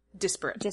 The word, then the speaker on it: disparate: These aren't really desperate shows. Desperate. – Disparate. – Yes, thank you.